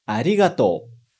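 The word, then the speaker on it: arigatou (ありがとう): E o sétimo e último exemplo de hoje é arigatou. Mais uma vez. Arigatou. De novo, repara que tem arigatou, mas eu não falo arigatou.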